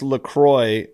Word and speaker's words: LaCroix: for me, so I'm on the coffee today, and you're on the liqueur. – Yeah, it's actually pronounced LaCroix, and I think it's originally from the Midwest, but it looks like this is made in California now.